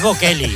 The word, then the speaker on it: Bocelli: Porque da boche. No, es Bocelli, guapo.